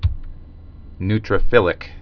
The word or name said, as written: neutrophilic